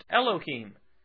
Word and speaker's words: Elohim: In the Hebrew, God is pronounced Elohim.